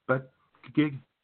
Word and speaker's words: Buttigieg: But we do need to, we're going to have to convene a committee to determine how to pronounce his last name. – Buttigieg. – A couple extra G's in there. Starts B-U-T-T.